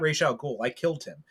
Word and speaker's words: Ra's al Ghul: It's Ra's al Ghul now.